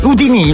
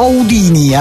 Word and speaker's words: Houdini: Con l'accento sulla I. Houdini o Houdini all'americana, ma comunque è gran personaggio mago.